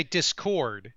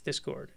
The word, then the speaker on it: Discord: You normally say Discord. – Discord. – Where you stress the second syllable, but you're saying, I think you've been trying hard lately. – Discord. It's Discord.